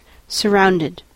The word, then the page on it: surrounded: American English